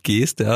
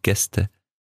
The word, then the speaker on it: Geste: Wir haben gelernt, Geste spricht man nicht mit Geste aus, sondern Gäste.